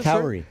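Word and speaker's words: Kauri: How do you pronounce it? – Cowrie. – Cowrie Cliffs. – A-U-R-I.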